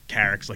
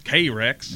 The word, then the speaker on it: Carex: Like we say Carex, like Carex Lurt or Carex Vulpinoidea. But then when I've talked to people from North Carolina or South Carolina, they always pronounce K-Rex. And I just found it so intriguing that it really, it was just a different enunciation and a Southern accent.